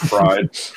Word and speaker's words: Fried: And every Fried is good. – Every time. – Homie said Fried.